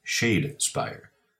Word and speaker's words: Shadespire: I also mispronounced this game as Shadowspire last week. It is, in fact, Shadespire.